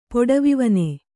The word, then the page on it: ಪೊಡವಿವನೆ: poḍavivane